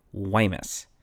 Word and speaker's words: Guaymas: Apparently, the port city in Sonora that I've been pronouncing as Guaymas should actually be pronounced with a soft G, so something more like Guaymas.